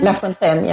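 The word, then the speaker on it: La Fontaine: Henri Lafontaine. – Lafontaine, yeah.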